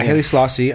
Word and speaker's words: Haile Selassie: Yeah, Haile Selassie.